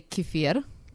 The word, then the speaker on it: kefir: In Russian, we say kefir. – Kefir.